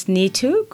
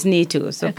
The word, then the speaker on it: Neetu: So my first name is Neetu. People go by the Neetu. – Okay. – And if it's hard to remember, I always say, it's nice to meet you, Neetu.